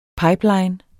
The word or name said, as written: PIPELINES